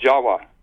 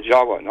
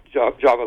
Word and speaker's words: Jawa: Furthermore, appending the suffix of T-I-S, the word Atlantis, it also is spelled T-I-V or pronounced T-I-W. These are pronounced as W's in Dravida, such as in Java. The word in Java, the island of Java, you know, is pronounced Jawa. Actually, Indonesians will pronounce it Jawa, not Java like we do.